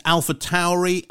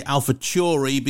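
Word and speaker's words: AlphaTauri: I've been told it's Alpha Tauri. I used to say Alpha Chori because I thought the Chori bit stood for Taurus, which you pronounce as Chori.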